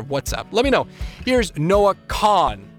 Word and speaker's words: Kahan: On top of it is Noah Kahan, because apparently Noah Kahan is wrong, and it's Noah Kahan. Also, it's poor. P-O-O-R.